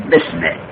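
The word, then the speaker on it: باسمه: خب پس بسمه جار مجرور در قانون رسم الخط به اسمه، اون الف اسم همزه وصله، در درج کلام ساقط میشه، باید تلفظ نشد. درج کلام یادت باشد یعنی در درج تلفظ، یعنی وقتی تلفظ میکنی اون همزه رو تلفظ نکن. پس مراد از کلام یعنی تلفظ، اما در نوشتن باید الف نوشته بشه. پس باید بنویسیم به اسمه. در سوره اقرا اونجا داره اقرا به اسمه، الف را نوشتن لیکن تلفظ نمی کنن، میگن بسمه.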